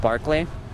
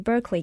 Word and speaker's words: Berkeley: Lord Berkeley. – It was a philosopher from Dublin, Ireland. It's actually supposed to be pronounced Berkeley.